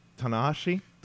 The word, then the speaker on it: Tanahashi: Uh, nine, uh, Tanashashi. Uh, I'm sorry, I butchered that one. Tana Tanahashi. Tanahashi.